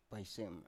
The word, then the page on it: paisem: Biak